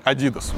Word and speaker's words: Adidas: А в моем детстве вообще неприятно ревновали, кто носит э, обувь Adidas, ну, сами понимаете, с чем Adidas рифмуется. Поэтому правильно говорить Adidas.